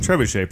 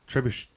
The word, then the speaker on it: trebuchet: Memes about the medieval siege engine known as a trebuchet, pronounced trebuchet. Trebuchet, yeah. – Ooh, should we be saying trebuchet? – Well, that's trebuchet. I guess I'm not. I'm doing a soft U, but mm-hmm. It's trebuchet.